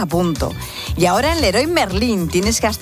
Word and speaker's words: Leroy Merlin: Leroy Merlin.